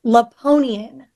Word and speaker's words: Lapponian: L-A-P-P-O-N-I-A-N. Laponian.